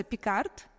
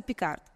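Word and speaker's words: Picard: Ты можешь посмотреть, называется Пикард, ну, наверное, по-французски Пикар, потому что, да, не читается, но вот называется Пикард.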